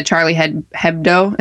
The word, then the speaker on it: Charlie Hebdo: Well, so kind of back to the Paris discussion, I was really intrigued with how the publication, the Charlie Hebdo, is that how you say it? – Yes.